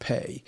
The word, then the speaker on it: Peh: P-E is how you spell it, Peh.